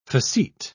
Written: facete

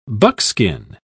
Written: buckskin

